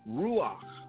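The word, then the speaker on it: ruach (רוח): The word spirit in Hebrew is pronounced ruach, which means to breathe or breath.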